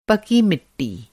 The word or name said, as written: पकी मिट्टी